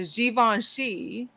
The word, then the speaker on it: Givenchy: The Givenchy.